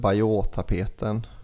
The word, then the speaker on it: Bayeux: Jag tycker att den här, vad heter den, Bayeux-tapeten är den första. – Fan vad allmänbildad du är. – Så du borde inte vara. – Fast det uttals Bayeux-tapet.